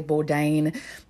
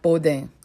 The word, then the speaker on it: Bourdain: So most people say Bourdain, but in French it's like Bourdain.